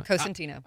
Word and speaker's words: Cosentino: Cosentino.